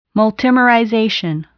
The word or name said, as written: multimerization